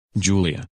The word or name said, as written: Julia